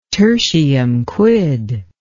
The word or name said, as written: tertium quid